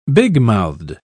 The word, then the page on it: bigmouthed: British English